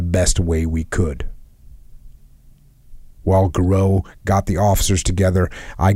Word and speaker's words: Giroux: Garou opened the message. That's one of the other guys, Garou. I hope I'm pronouncing that right. G-I-R-O-U-X, Garou, opened the message.